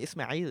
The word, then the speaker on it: Ismail: I don't know if I'm saying it because in a Palestinian dialect, or I guess I'm putting the emphasis on the second part. I'm saying Ismail versus Ismail. – Oh yeah, I mean Ismail is very Arab.